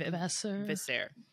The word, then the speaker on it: Vasseur: Fred Vasseur.